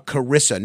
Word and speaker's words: Charissa: FYI, it is Charissa. Yes, it's pronounced like a Carissa, no ch sound. Okay, Carissa.